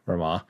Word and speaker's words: Ramuh: Apparently, and this is according to Twitter, so it must be true, Ramuh, apparently is supposed to be pronounced Ramu, as in R-O-M-O-O.